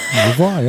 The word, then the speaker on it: Au revoir: Au revoir. Is that how you say it? – Au revoir, yeah.